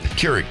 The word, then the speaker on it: Keurig: Johnny brought in a coffee maker because Krieg. – Krieg. – Keurig. – Keurig. Keurig. Yeah. You don't even know how to say it.